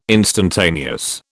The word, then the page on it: instantaneous: British English